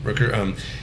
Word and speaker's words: Rooker: Rooker.